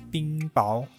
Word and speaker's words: 冰雹: 不太确定这个发音哈，我上到这个教育百科上面查呢，是念冰雹，那我等一下就念冰雹喽。